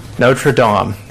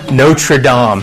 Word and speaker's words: Notre Dame: Notre Dame. Is that how you say it? – No. – You don't say Notre Dame. Okay. Notre Dame.